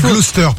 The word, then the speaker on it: Gloucester: Alors, on dit Gloucester pour info. Ça s'écrit Gloucester, mais c'est pour ton info.